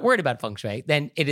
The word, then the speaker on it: feng shui: Yeah, and if you want to pronounce it correctly, listen. Feng shui. – Wind and water. Feng shui.